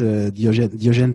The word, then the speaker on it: Diogene: mine is Diogen Tirandekura, and I pronounce it with the French French accent that I have.